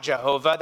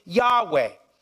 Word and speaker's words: Yahweh: Some have, through Latin influence, have pronounced it Jehovah. You might have heard that, Jehovah. That's from some Latin influence. Some have taken the vowels from Adonai and put them between YHWH and pronounced it Yahweh.